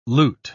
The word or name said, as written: loot